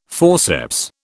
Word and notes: forceps: British English

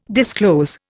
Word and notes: disclose: Indian

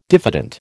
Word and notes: diffident: American English

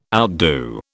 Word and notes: outdo: British English

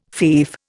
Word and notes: fief: Indian

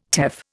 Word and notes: teff: Indian

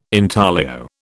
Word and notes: intaglio: British English

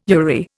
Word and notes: URI: Indian

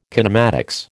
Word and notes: kinematics: American English

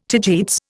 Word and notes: tagetes: Indian

